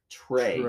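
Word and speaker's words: Trea: His is spelled different, but it's still pronounced what? – But it's spelled saucy. – Pronounce it. Trey.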